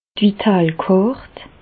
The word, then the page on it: d' Vitale Kàrt: Bas Rhin; Reichshoffen